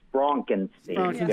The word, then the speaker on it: Frankenstein: Well, it's very, very precise. It's Frankenstein. Very important.